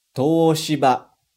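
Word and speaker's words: Toshiba (東芝): Repara que depois do to tem um u, mas na verdade a gente não fala exatamente u. Presta atenção na minha pronúncia. Toshiba. Toshiba. Viu? Eu não falei Toshiba. Não falei o som do U. Eu só estiquei o som do O. Como se tivesse dois Os, assim.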